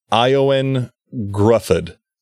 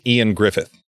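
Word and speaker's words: Ioan Gruffudd: his name looks like it's spelled like Eowyn Gruffudd, but apparently it's Ian Griffith.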